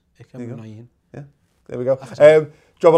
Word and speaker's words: Muniain: Bit of Ike Muniain. – I'm still learning not to say it. – Well, that's how you say it.